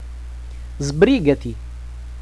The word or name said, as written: sbrigati